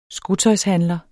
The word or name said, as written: skotøjshandler